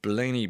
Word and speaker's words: Blaney: National Collective Community-Based Women's Networks and the Blaney Blades. Is that, am I saying that right? Yes.